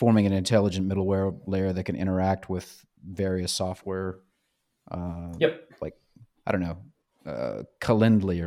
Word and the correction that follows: Calendly: Calendly. Sorry. I don't know why I put the the wrong emphasis on the wrong syllable.